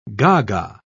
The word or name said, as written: gaga